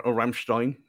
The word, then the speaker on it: Rammstein: I mentioned on Twitter about the instrumental version of Mein Heart Brennt, which would be a turning point, playing a bit of Rammstein. Or Rammstein, if you want to say it properly, but the instrumental version of that, it's only a bit like the is it the Dancing of the Night that Sunderland play?